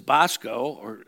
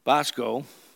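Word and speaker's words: bosko (βόσκω): So I looked up the Greek, which is bosko, the first O is pronounced in a short O sense, and the second O is omega, which is pronounced like a long O, bosko, which means to grade, to feed, and to keep sheep.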